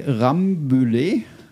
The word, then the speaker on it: Rambouillet: Jetzt, wenn ich es nur aussprechen könnte, Rambouillet mhm.